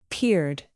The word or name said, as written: peered